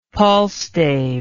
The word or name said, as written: palstave